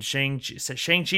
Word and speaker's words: Shang-Chi: There's some weird ones in there between Shang-Chi.